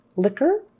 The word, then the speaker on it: liquor: Racket, racket, silent U, liquor, and lacquer, both silent U. Now, some words have Q-U-E on the end, and the U and the E are silent.